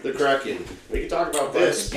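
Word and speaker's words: KRAK'IN: And it's spelled K-R-A-K. apostrophe I-N. – Nice. – It's called Kraken.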